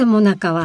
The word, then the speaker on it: 最中: なぜもなかは最中と書くのでしょうか。もなかというのは訓読みで、日本古流の読み方はこちらの方。最中というのは明治以降に現れた新しい読み方なのだそう。